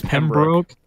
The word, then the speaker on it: Pembroke: That's Pembroke. It's Pembroke. – Okay.